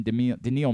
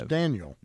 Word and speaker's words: Daniil: How do you pronounce Medvedev's first name? – Daniil. – Daniil. – Daniil Medvedev. – I always thought it was Daniel.